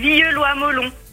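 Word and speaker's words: Villieu-Loyes-Mollon: Alex, je t'en supplie, donne-moi exactement la prononciation du lieu où tu habites. – Alors c'est Villieu-Loye-Melon. – Villieu-Loye-Melon.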